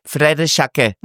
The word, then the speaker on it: Frère Jacques: Weil das heißt nämlich wirklich, viele denken, es heißt Frère Jacques. Nein, es heißt Frère Jacques.